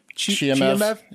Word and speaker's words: Chimaev: Chimaev.